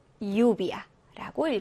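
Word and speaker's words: lluvia: 네, 끝에서 두 번째 음절의 강세가 오게 됩니다. 그래서 니에베 그리고 유비아라고 읽어주시면 돼요.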